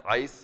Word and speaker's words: eis: Just when you see the word on, many times it's this Greek word that's E-I-S, ice. I guess it's not E-I-S, it's the Greek letters, but it's pronounced ice, and it has to do with that final resting place.